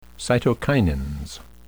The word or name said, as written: cytokinins